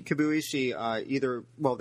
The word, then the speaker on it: Kibuishi: Kibuishi.